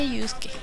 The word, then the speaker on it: Yusuke: ¿Cómo le dirías tú? ¿Yusuke o, yo, o Yusuke? Se pronuncia Yusuke, así que le diremos Yusuke.